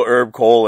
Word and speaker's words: Kohl: I had to Google Herb Cole, and it's spelled K-O-H-L. It's not even C-O-L-E.